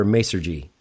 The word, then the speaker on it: Masergy: Ray is the VP of Global Technology for Masergy.